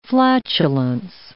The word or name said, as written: flatulence